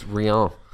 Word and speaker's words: Rian: Nicholas Rian. Am I pronouncing that right? – Yeah, Craig Jones as well.